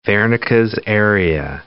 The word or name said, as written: Wernicke's area